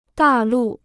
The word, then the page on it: 大陆: dà lù